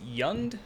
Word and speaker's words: Jund: And then in eighth place, we have a little scene color combination of black, red, and green, and a deck that I think is pronounced Yund. I believe it's a soft J.